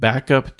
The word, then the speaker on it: Bvckup: For Windows, I recommend, it's hard to say, you'd probably want to pronounce it Backup to Professional, but Backup is spelled BV instead of A, BVCKUP.